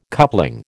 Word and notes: coupling: American English

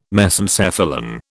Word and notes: Mesencephalon: British English

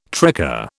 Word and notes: tricar: British English

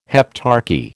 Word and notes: heptarchy: American English

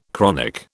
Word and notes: chronic: British English

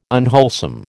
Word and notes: unwholesome: American English

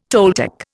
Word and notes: Toltec: Indian

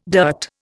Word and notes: dirt: Indian

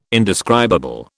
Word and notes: indescribable: British English

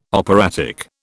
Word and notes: operatic: British English

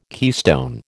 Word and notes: keystone: American English

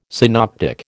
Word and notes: synoptic: American English

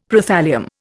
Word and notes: Prothallium: Indian

